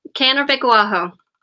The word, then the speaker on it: Kanervikkoaho: Kanervikkoaho.